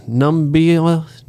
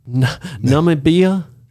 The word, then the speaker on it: Namibia: In Germany, South Africa, Italy, Austria, and then I don't even know how to say the name. Namibia? – Yeah, that sounds right. – Namibia? – Yeah.